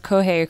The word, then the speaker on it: Kohei: The only reason I know how to pronounce Kohei correctly is because a really famous gymnast is named Kohei Uchimura. So. – Oh, okay. – Kohei.